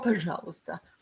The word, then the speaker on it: пожалуйста: Oh, пожалуйста.